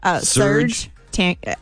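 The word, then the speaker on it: Serj Tankian: Serge Tankian.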